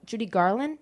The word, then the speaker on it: Judy Garland: Oh, am I saying her name right? Judy Garland? – Yeah, you are.